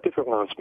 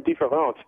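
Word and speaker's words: différance: And what différence means, and you'll note that the word is indistinguishable from the French word for difference, différence. The only difference between them is that the one word is spelled E-N-C-E, and the term différence is spelled A-N-C-E.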